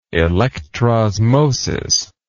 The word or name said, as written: electrosmosis